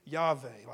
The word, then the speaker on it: Yahweh: I say Yahweh, like Y-H-W-H. Now, the W is pronounced like a V.